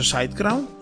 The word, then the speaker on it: SiteGround: Si scrive SiteGround, ok, si pronuncia SiteGround, e penso che siano spagnoli come base, e loro mi trovano molto bene, funziona tutto da Dio.